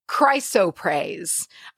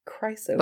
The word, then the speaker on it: Chrysoprase: Chrysoprase. I struggle praise. With this word because it doesn't seem real. None of these syllables seem like they should go together. The oprase is weird to me. – Chrysoprase.